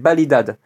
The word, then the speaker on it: Validada: Donc Validade qui s'écrit V-A-L-I-D-A-D-A, mais le A final en gascon qui se prononce E, donc que n'est pas Validada, qui est Validade.